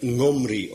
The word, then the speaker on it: Omri: Men veronderstelt meestal dat het dichtst in de buurt komt van onze NG-klank, dus een keelklank. Zodat het Nomri of zoiets geweest zal zijn.